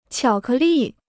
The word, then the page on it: 巧克力: qiǎo kè lì